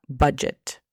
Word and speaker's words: budget: Bueno, aquí la pronunciación fonética es C-H-E-I-N-G. Change. – Change. – Muy bien. Por último, tenemos la palabra budget. Budget significa presupuesto. La pronunciación fonética es B A CHET.